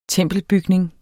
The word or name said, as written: tempelbygning